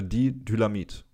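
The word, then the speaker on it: Diethylamid: Äh, nee, nochmal von vorne. Lysergsäure Didylamid. Also wie Dynamit, Dynamit. nur ein bisschen anders.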